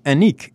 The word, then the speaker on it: Annick: Yes, she is also Annick, A-N-N-I-C-K, pronounced A-N-N-I-Q-U-E, Annick.